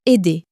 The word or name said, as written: aider